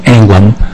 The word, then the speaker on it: Anglim: I am Richard Anglim, the last name is spelled A-N-G-L-I-M, and I represent the 5th District of the Department of Minnesota.